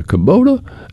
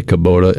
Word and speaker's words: Kubota: Follow-up from Moses, and Moses was talking about zero-turn mowers, and he's got a Kubota, and I say Kubota, and people say Kubota, but I don't care.